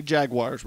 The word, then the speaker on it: jaguar: Have you shot any jaguars recently? – Oh, I went to the zoo the other day, and it says jaguars are pronounced jag, W-A-H, and in England it's jaguar, how it's spelt.